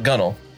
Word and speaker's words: gunwale: Like, that's not a spelled gun whale, but you pronounce it gunwale.